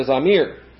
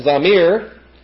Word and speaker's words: zamir: We go from Zamer to Zamir. And Zamir is the noun form, which means song. And